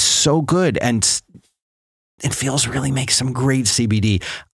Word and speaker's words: Feals: Feels, which is spelled F-E-A-L-S, it is a premium CBD that is delivered directly to your doorstep.